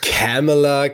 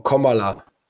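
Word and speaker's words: Kamala: Alter, ob die jetzt Camela, Carmela oder irgendwie. – Wie Komma und dann La. Nicht so schwer. Kom Komala.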